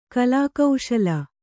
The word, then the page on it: ಕಲಾಕುಶಲೆ: kalākuśale